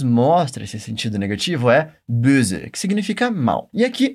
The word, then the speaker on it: Böse: Aqui a gente tem o som de O, umlaut, que é aquele O com biquinha que nós temos no alemão. Repete comigo. Böse. Böse.